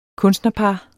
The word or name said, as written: kunstnerpar